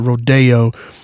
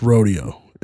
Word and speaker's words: Rodeo: So it sounds only right to pronounce it Rodeo, but that road will actually take you into the hood, and it's pronounced Rodeo in the hood.